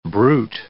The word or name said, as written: bruit